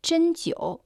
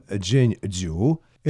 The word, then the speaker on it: 针灸: Иглоукалывание и прижигание на китайском языке звучит как «чэньтио». Первый иероглиф «чэн» произносится в первом тоне. В переводе это «игла», иглоукалывание. Второй иероглиф «тио» произносится в третьем тоне.